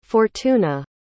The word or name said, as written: fortuna